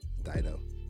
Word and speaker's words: Dido: Is it Ditto? – Nah, close though. Dido. – You for real? How you say it? For real? – Yeah, it's Dido.